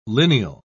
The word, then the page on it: lineal: British English